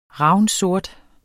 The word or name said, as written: ravnsort